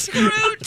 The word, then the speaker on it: Scrooge: Scrud. – I meant... – Scrooge. – I meant... – Yeah, you might be. – I meant... I did... – Scrooge? – No, I said it wrong. I said... I meant... Forget it. Forget it. – Scrud, Scrooge. Tomato, tomato.